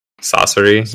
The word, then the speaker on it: Sasori: Sasori? Is that how you say it? – Saucery.